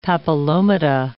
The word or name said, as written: papillomata